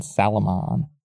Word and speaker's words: Salomon: Armis and Salomon. Kind of like salmon, but where you really pronounce the L.